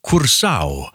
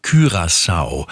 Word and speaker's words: Curaçao: Ich habe mal rausgesucht, wie die Locals denn ihr eigenes Land aussprechen. Die sagen Curaçao. So, bei der WM-Übertragung werdet ihr aber meistens eine andere Aussprache hören. Da geht es so ein bisschen in die französisch-holländische Richtung, und zwar Curaçao.